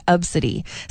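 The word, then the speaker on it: Abcde: It's A-B-C-D-E. That's how you pronounce it, Obsidi.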